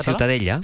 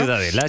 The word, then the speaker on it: Ciutadella: La Ciutadella. – Ciutadella. Bueno, pues Ciutadella también. – también en castellano. – Ciudadela, sí.